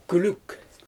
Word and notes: kɪɪwk: Xyzyl

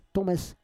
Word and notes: tomes: Roon

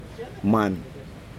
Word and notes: man: Aneityum

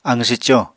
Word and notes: aqw swco: Apatani

